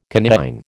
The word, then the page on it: coniine: American English